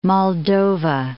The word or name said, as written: Moldova